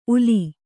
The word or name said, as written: ಉಲಿ